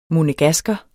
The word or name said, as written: monegasker